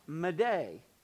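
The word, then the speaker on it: m'aider: and he essentially chose the French word, pronounced variously, but pronounced Mayday, M, italics or apostrophe, A-I-D-E-R. Well, that became Mayday, M-A-Y-D-A-Y.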